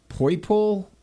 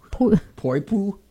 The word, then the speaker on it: Poipu: The number one beach this year is Poipo Beach in Hawaii. Okay, pronounce this one. P-O-I-P-U. Poipo? Poipo? – Poipo.